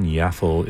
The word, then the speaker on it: Yaffle: Yaffle is spelled like Raffle, except with a Y at the beginning.